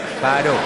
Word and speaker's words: Phideaux: You spell that P-H-I-D-E-A-U-X, Fido.